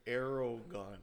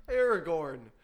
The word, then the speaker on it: Aragorn: You just pronounced Aragorn Aragon. – Aragon. – Oh, my God. – Aragon. How do you pronounce it? How do you pronounce it? – Aragorn.